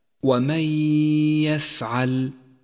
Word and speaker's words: ومن يفعل: والواجب هنا أن نسقط النون الساكنة من اللفظ وأن نقف على حرف الياء بعدها بغنة زمنها حركتان. وقد لونت النون في حكم الإدغام بالرمادي إشارة إلى عدم نطقها، ولون الحرف الذي بعدها بالأخضر إشارة إلى موقع الغنة، أي هكذا: ومن يفعل، ومن يفعل.